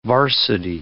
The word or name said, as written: varsity